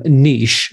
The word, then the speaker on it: niche: So Susan's going to say niche, so I will switch from niche, the correct pronunciation.